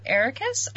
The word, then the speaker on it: Eraqus: So, uh, you take him to Eraqus. I think is that name. What's and, uh, Eraqus? What, what, what is that name? Like, uh, uh, you're right. You pronounced it right. You said it right.